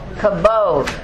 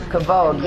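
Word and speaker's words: Kabod (כבוד): So we have been in the Glory of God series, and glory, you guys who know this, glory in the Hebrew is pronounced what, how? Kabod. Very good. Kabod, yes. And it means what? – Weightiness.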